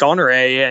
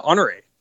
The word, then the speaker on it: Honoré: And Trey was actually like, "Well, what about Honoré?" And the street name doesn't have the accent over it, but we were like, "Oh man, people from out of town will never know how to pronounce this."